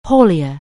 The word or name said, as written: haulier